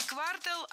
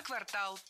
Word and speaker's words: квартал: Не квартал, а квартал. Причем такое ударение актуально при любом значении слова. И часть улицы, и часть года. Всегда в слове «квартал» ударяем второй слог.